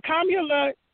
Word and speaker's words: Kamala: And Kamala, they say when you say her name, pronounce it like the punctuation, Kamala.